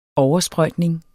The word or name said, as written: oversprøjtning